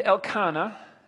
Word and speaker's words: Elkanah: Samuel tells us there was a man named Elkanah, as it's pronounced, who lived in Ramah in the region of Ziph, in the hill country of Ephraim.